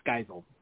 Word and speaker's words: Geisel: And the first name, Giselle? What? – Geisel. It's Geisel. – Geisel. Oh, Geisel. I made it French.